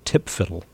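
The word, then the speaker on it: TPFDL: which is this thing whose acronym is pronounced TIPFIDDLE, the time-phased forces deployment list.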